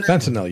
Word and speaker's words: fentanyl: Fentanyl, you got it.